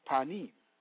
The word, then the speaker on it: panim: The Hebrew word for presence is panim, pronounced panim, meaning the face as the part that turns.